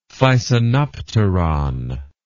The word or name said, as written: thysanopteron